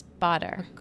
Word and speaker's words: Augustinus Bader: Oh, by the way, did you know we both say it wrong? It's Dr. Bader. – Oh, is it? – Yeah, the publicist. I saw her for drinks, and I was like, oh, whoops. – Oh, okay. Yeah. Good to know. – But I still say Bader.